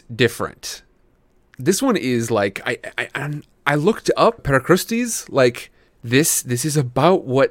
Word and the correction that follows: Procrustes: Pro Christie's, there we go. Not Per Christie's, it's Pro Christie's.